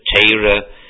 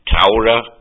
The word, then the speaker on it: Torah: Litvish usually pronounce it as a Teira, Yekis with an Au, Taura, and others as an O.